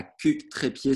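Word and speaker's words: Cucq-Trépied: Alors, Pauline, t'es née le 31 janvier 86 à Cuc-Trépied, c'était la plage. Je l'ai bien prononcé ? – Ouais, c'est ça.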